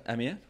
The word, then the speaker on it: Amiens: Yeah, is that how I say it? Amiens? Wow. Look at that. I've been listening to your French. – Très bien, prononciation. C'est parfait.